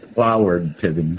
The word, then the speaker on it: Cloward Piven: This is, and as a matter of fact, what is it, Cliven Piven, or how do I pronounce that? – Cloward Piven. – Cloward Piven, thank you.